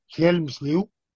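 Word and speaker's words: Hjelmslev: que es Helmslev, escrito Lev, pero mi maestro en la PUCE de filosofía del lenguaje me dijo que había que pronunciar Helmslew, y bueno, pues yo desde entonces así lo.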